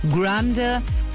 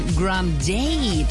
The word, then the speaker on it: Grande: Grande, eigentlich, ihr Bruder hat mal irgendwann Grande draus gemacht. Wir können auch Grande sagen. Ihr ist es egal.